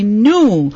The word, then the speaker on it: gnu: The clue was not old, and a lot of kids said young, but the answer is actually new, because we were looking for the name of an animal, and the spelling is G-N-U. It's pronounced either as new or new.